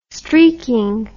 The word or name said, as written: streaking